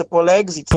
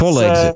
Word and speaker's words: Polexit: йдеться про поєднання слів «Poland» і «Exit». Тобто те, що... – Так, це «Polexit». – «Polexit». – Це нагадує слово «Brexit».